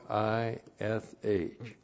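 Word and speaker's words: ish (איש): Aleph, long I, S-H. Now here is the word for man, ish.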